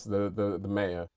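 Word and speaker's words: Maior: Yes, the Mayor. – And so it's M-A-I-O-R. – Yeah, it's Latin for great.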